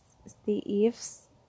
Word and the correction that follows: St. Ives: apa sih bacanya Kenora deh ya pokoknya Ives itu yang Steve Saint Ives kan Saint Ives St. Ives yaitu Saint Ives ya Allah noraknya Cu,